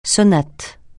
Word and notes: sonate: francais

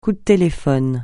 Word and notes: coup de téléphone: French

